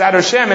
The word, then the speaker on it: Adoshem: And that is because the Shem Hashem is pronounced as Adoshem instead of Yud-Kei Vav-Kei.